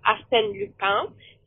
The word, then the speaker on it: Arsène Lupin: And it's pronounced Arsène Lupin.